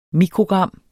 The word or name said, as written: mikrogram